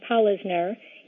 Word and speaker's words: Polisner: The easiest way to remember how to pronounce my last name, Polisner, is to think of the word polisher, like a shoe polisher, and then replace the H with an N. Polisner is a pretty rare name, so if you meet another person named that, they are probably related to me.